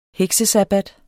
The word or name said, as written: heksesabbat